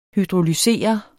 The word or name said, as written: hydrolysere